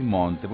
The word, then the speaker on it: Monte: ¿Con qué alegría recibo cada disco nuevo de Marisa Monchi? Voy a decir Monte, porque no me siento cómodo diciendo Monchi.